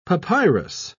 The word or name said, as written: papyrus